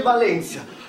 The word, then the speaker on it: Valentia: Poi le province, Cosenza, e poi dal 1992 altre due, Crotone e Vibo Valentia, scritto Valentia, si legge Valentia.